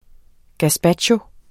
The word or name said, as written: gazpacho